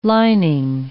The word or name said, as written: lining